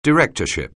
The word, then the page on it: directorship: anglais